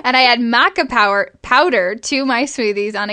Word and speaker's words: maca: And I add maca, is that how you say it, Steph? – Maca, yeah.